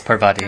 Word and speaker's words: Parvati: Is it Parvati? – It's Parvati. Okay.